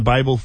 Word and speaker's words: Babel: B-A-B-E-L. What does that spell? – Babel. – Babel, as in the Tower of Babel. – Yes, as in lots of languages. The Babel fish in Hitchhiker's Guide.